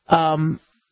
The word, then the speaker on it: Bamlanivimab: And so the single antibody treatment, I talked about it the other day, 2 days ago or so, Bamlanivimab is how you say it. Hard to say, right?